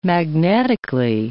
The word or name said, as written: magnetically